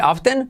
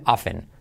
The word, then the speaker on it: often: Curiosamente se puede decir often, pero lo más habitual es decir often hacen.